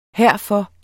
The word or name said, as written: herfor